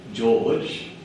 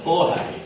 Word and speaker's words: Jorge: His birth name is, now, we would probably say George, but you don't pronounce it at all, and it's Jorge, so J-O-R-G-E, George or Jorge, Mario Bergoglio.